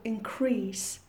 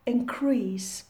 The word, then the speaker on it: increase: And please pay attention to the verb increase. As a verb, we pronounce it increase, but as a noun, we pronounce it increase. The stress falls on the first syllable.